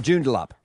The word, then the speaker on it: Joondalup: Oh, Joondalup.